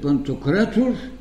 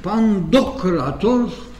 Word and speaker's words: Пантократор: Разбира се, на едно место тя е пантократо, което е съвсем верното по гръцкото ударение. Тук представя преподавател по старогръцки има моя стара познайница. Другото е и пандократор, но да оставим промените.